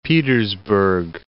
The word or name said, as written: Petersburg